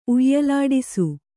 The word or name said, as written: ಉಯ್ಯಲಾಡಿಸು